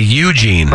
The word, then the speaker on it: Eugene: Some people also really punch the first syllable of that. They say Eugene.